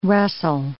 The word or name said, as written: rustle